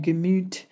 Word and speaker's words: Gemüt: It's called Gemüt. I don't know if I'm pronouncing that properly. – That's correct. Yep.